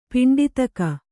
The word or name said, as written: ಪಿಂಡೀತಕ